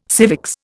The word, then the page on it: civics: Indian